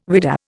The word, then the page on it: whidah: Indian